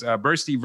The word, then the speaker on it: Boerste: And the name of this case was Boerste versus the University Medical Center, Inc., DBA, as University of Louisville. Did I say that right? Hospital? – There you go.